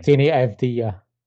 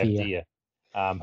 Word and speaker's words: Avdija: Deni Avdija. – Yeah, Avdija.